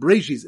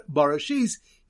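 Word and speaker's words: Bara Shis: Elabarashis do not pronounce it brachis in the beginning, but rather Barashis, he created Shis.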